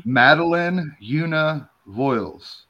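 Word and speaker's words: Madeleine Yuna Voyles: Madeline Una Voiles.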